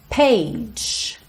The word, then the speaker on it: page: Across the page, page, o E final não é pronunciado.